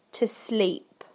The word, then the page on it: to sleep: British English